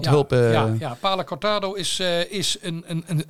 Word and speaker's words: Palo Cortado: En hier ga ik waarschijnlijk eh, de, de, de, de fout in met Palo Cortado en Oloroso sherryvaten. – Ja. – Heb ik het goed uitgesproken? Heel goed.